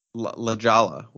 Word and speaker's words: La Jolla: Every time he talks to you on the podcast, and you basically pronounce like you know you pronounced La Hoja or whatever he said. – La Jolla.